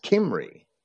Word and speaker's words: Cymry: When they passed to Britain, they maintained that name as Cymry. And it's spelled a little different, but it's pronounced almost the same, Cymry, Cymry.